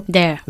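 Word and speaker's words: they're: ¿Y cómo pronunciamos la contracción de they are? No se dice they, sino there. – There. – There. – Sacando la lengua.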